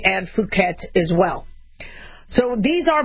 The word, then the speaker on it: Phuket: Make sure you say it long U, because if it's short U, it can get us in trouble on air. But it's Phuket. That's also a B strain.